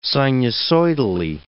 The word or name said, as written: sinusoidally